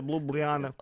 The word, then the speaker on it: Ljubljana: You got to say Ljubljana.